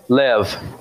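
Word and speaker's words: Lev (לב): What is heart? We'll just pronounce it. Lev. Lev. So heart is lev.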